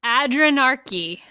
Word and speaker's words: adrenarche: It is called Adrenarche.